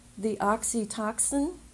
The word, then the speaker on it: oxytocin: And just bringing that memory up, that positive memory, will release the oxytocin. Am I saying that right? Yes.